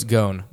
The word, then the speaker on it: Ghosn: It's spelled G-H-O-S-N. I don't know how you pronounce that. Carlos Ghosn. That's it. It's Carlos Ghosn.